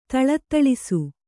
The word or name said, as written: ಥಳಥಳಿಸು